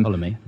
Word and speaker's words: Ptolemy: Antigonus, Seleucus, and Ptolemy, don't know how to pronounce them. – Ptolemy.